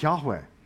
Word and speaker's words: Yahweh: How do you pronounce that? The best way to pronounce that is Yahweh.